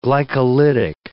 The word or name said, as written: glycolytic